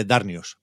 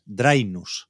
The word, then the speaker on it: Drainus: Está el, siempre lo digo mal, Víctor, siempre me sale Darnius, pero no, es Drainus. Drainus.